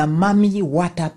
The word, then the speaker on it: Mami Wata: In Ghana, they believe in what is referred to as the Mami Wata people. Mami is spelled M-A-M-I, then Wata, W-A-T-A, the Mami Wata people.